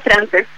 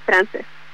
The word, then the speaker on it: Frances: Sí, está bien dicho, Frances. Siempre tengo como esa confusión con la gente que no sabe cómo pronunciarlo. Pero sí, está bien, es Frances.